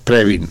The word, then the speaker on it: Previn: Previn, sí, aquí sabemos cómo se pronuncian las chingas.